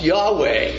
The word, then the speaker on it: Yahweh: So whenever you see in most Bibles all capital letters for the word Lord, that's the word best pronounced Yahweh.